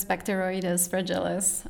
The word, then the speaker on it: Bacteroides fragilis: It is Bacteroides fragilis.